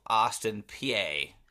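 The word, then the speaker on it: Austin Peay: And then 12, Austin, PA. – I believe it's just Austin, P. It's spelled PA, but I believe it's pronounced P.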